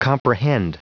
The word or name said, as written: comprehend